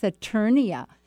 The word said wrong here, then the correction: Saturnalia: Oh, Saturnalia, yes. – How do you say that? – Happened. Well, I don't know if I'm saying it right, but I think it's Saturnalia.